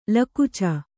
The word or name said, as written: ಲಿಕುಚ